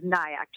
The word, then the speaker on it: Nayak: It's T-A-N-I-Y-A. My last name is Nayak, just like kayak with an N, N-A-Y-A-K.